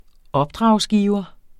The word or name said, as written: opdragsgiver